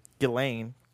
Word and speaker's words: Ghislaine: Just Ghislaine, excuse me. – Ghislaine. – Yeah, the S is silent.